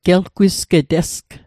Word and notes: gelgwisgetesg: Mi'gmaq

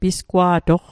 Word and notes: pisgwa'toq: Mi'gmaq

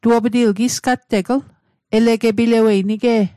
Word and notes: elegepilawet: Mi'gmaq

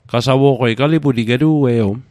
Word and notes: qasawo'qei: Mi'gmaq

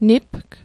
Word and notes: nipg: Mi'gmaq

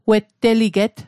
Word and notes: wetteliget: Mi'gmaq

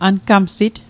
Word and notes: angamsit: ankamsit; Mi'gmaq

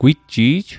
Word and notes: gwitji'j: Mi'gmaq

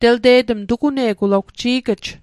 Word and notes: tugune'g: Mi'gmaq

